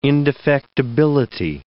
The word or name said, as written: indefectibility